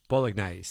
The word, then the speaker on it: bolognese: One of you has taken the time to write in and very cheerily said, bolognese, say it properly. Bowl, not bog.